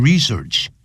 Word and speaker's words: research: In order to research the origins of the English language，为了要研究这个 research，名词动词都念成 research。